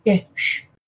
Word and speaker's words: ghoti: The G-H is pronounced f, as in the end of rough. Yep. Tough. Enough. The O is pronounced I, as in women. And the T-I is pronounced sh, as in station, portion. You could have the other idea, fish, fish. G-H-O-T-I.